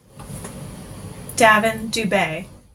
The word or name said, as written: Davin Schnappauf